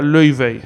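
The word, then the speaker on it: Laufey: Bueno, se escribe Lau Fei, pero se pronuncia Lui Fei.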